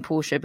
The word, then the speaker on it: Porsche: And I'm saying Porsche because I've been put right several times on Twitter this week about the pronunciation.